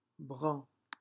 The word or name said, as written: brun